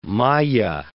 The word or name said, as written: maya